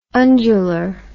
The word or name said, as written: undular